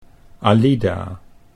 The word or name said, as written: Alida